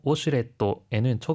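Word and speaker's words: 워슈레트 (ウォシュレット): 하이, 토이레트, 그리고 워슈레트에는 초음이 들어가고요. 페이퍼에는 자음이 두 개 들어가는 거에 주의해 주세요.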